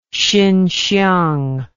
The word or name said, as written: xinxiang